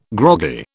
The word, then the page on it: groggy: British English